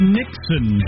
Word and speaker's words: Niksen: The Dutch call it Nixon, Joe. – Nixon like the president? – No, spelled differently. – Oh. Phonetically like the president. – N-I-K-S-E-N, Nixon.